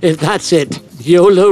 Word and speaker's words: YOLO: YOLO wasn't a waste of time getting into. That sounds like it's on track to be good. It rhymes with polo.